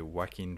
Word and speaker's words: Joaquin: We follow Arthur Fleck, who's played by Joaquin Phoenix. Yes, that's how you pronounce his name. I did look it up.